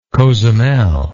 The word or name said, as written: cozumel